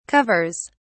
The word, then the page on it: covers: British English